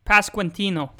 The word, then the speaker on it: Pasquantino: Pasquantino.